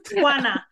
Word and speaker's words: Juana: You did it very, very well. Juana. It's like Juana.